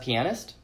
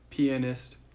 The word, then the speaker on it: pianist: I think what would kill me is knowing you could be the world's best pianist, but you would never be able to get back to share it with. How do you say it? – Pianist. Like penis, yes. But pianist. It's pianist.